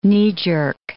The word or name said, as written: knee-jerk